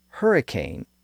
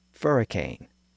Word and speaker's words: hurricane: That newer pronunciation with the H sound became common in Spanish over the course of the 1500s, and by the late 1500s, it had become common within English as well, so much so that it pushed out the alternate English pronunciation of "furricane."